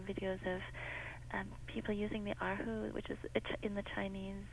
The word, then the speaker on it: erhu: How do you pronounce the musical instrument? – Erhu. – Yeah. – Yes, you're very close. You're just right, erhu.